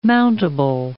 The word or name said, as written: mountable